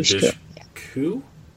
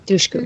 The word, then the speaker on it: Dushku: Eliza Dushku. – Dushku.